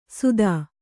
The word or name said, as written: ಸುಧ